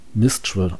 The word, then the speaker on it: Minstrel: Also es wird Minstral geschrieben, aber es wird, glaube ich, also Minstrel ausgesprochen.